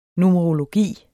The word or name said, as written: numerologi